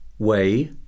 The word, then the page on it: weigh: verb